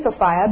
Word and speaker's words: Sophia: So I want to show you where I met Sophia, and I'm saying Sophia because that's how you say it in the East.